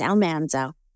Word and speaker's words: Almanzo: Correct pronunciation, by the way, is Almanzo.